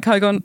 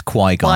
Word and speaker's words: Qui-Gon: It's just the Did I say his name right, Qui-Gon? – No, it's Qui-Gon.